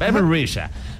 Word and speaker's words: Rexha: Bebe Risha.